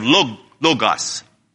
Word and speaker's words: logos: So I will give that O a long O sound like in the English language, and the other O would be like this pronunciation of the word hot. So you have logos. Yeah, you got that? You got the pronunciation there? It would be like a long O, the first O, and the second O would be more like a, as I said, logos.